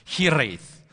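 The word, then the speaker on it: hiraeth: I think you're supposed to roll the R, but it's hireth.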